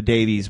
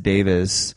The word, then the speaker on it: Davies: So we say you know the Davies brothers in the states, but that's not really how it's pronounced. It's pronounced Davis.